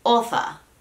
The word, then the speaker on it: author: Enfoquémonos en author. Author. Después del th, váyanse directo al r. Ni se fijen si hay una O ahí, porque la verdad es que ni importa. Suele suceder con palabras en inglés que con tal de tener la sílaba tónica bien pronunciada, lo demás no importa tanto.